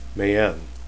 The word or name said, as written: mayene